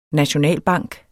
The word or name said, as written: nationalbank